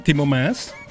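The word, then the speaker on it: Maas: ¿Lo dije bien? ¿Timo Mas? – Yes.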